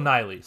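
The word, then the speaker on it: Nighy: Like, Bill Nye wanted to play Lockhart. – The science guy? – No, the... Bill Niley, sorry, I mispronounced his name.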